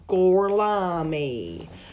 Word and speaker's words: Gorlami: They snatch up Gorlami. – Gorlami. – They throw a bag. How do you say it? – Gorlami.